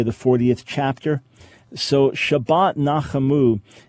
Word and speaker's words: Nachamu: It says it has a special name for this week's Shabbat, and it's in Shabbat, and then NACHAMU, and I was wondering if you could tell me how to pronounce that and what it means. – Yeah, just the way you spelled it.